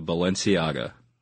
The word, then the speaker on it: Balenciaga: Balenciaga. – Balenciaga.